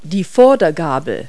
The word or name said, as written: fork